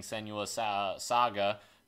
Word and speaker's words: Senua's Saga: The Hellblade team is making Senua's Saga.